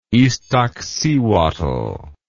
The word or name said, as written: Ixtaccihuatl